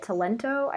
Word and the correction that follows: Talenti: ice cream or Talenti.